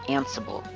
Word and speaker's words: Ansible: This episode's about Ansible, hard S, soft S, whichever one you prefer doesn't matter to me.